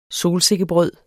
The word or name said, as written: solsikkebrød